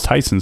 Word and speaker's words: Theisen: Yeah, but how's Tyson spelled? It's like Thiessen. – It is spelled like Thiessen. T-H-E-I-S-E-N, but it's pronounced Tyson.